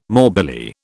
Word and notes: morbilli: British English

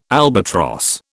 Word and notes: albatross: British English

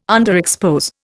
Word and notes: underexpose: Indian

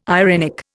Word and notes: irenic: Indian

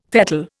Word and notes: fettle: Indian

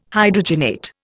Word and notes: Hydrogenate: Indian

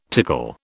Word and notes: tickle: British English

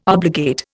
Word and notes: obligate: Indian

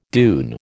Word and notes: dune: American English

